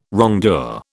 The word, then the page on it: wrongdoer: British English